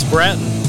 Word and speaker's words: Brattain: It's Brattain.